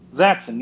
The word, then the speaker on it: Zaxxon: So, and this was probably like a game when Sega used to like sell out their franchises and like because like Zaxxon was on. Is that how you pronounce that game? – Zaxxon. Yes, you pronounced it correctly.